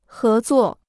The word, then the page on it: 合作: hé zuò